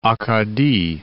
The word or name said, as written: Acadie